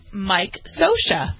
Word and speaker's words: Scioscia: S-C-I-O-S-C-I-A. And it's pronounced Scotia? – Scotia.